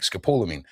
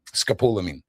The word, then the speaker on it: scopolamine: But if you harvest certain parts and process them, you make scopolamine. And they show stories of people. – How do you pronounce it? – Scopolamine.